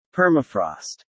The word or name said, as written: Permafrost